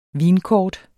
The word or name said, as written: vinkort